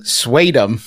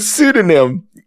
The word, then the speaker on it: pseudonym: I have a friend who is intelligent and well-read, but for reasons unknown, she mispronounces the word pseudonym, saying swadum. – Oh, man. – Instead of pseudonym.